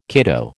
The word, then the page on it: kiddo: American English